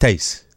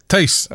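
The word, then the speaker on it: Tijs: T-I-J-S. T-I-J-S. What name is that? – Oh, your. – What is it? – I think that I actually think you pronounce it like Tace. – Tace.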